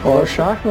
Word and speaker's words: Rorschach: Rorschach lá.